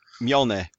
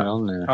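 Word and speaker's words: Mjölnir: Mjolnir. – Yeah, Mjolnir.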